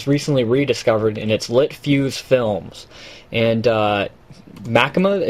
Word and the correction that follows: machinima: It's a Machima, Machinima, whatever. Machinima site where they create various Machinima videos. – Sorry, I was just going to say, somebody told me that it's pronounced machinima, similar to cinema.